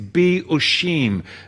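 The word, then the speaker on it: be'ushim (באושים): The Hebrew word here is be'ushim.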